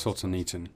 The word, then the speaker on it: Solzhenitsyn: But, like Rocky said, Alexander Solzhenitsyn, I cannot pronounce Solzhenitsyn.